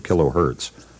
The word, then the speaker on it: kilohertz: Oh, and by the way, we should comment that it's perfectly proper to pronounce that word kilohertz. Hertz like it hurts when you hurt yourself.